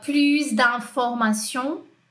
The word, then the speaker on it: plus d'informations: É, vai aprender também que é plus D, aqui eu vou pronunciar o S, né? Lembrando de como ela pronunciou, como essa voz aí mecânica pronunciou. Uh, plus d'informations, plus d'informations, mais informações, no, que é o O.